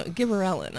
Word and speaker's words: gibberellin: Another gibberling one I would never have gotten. – No, gibberellin. I'm sorry. I pronounced that wrong.